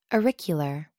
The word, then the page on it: auricular: American English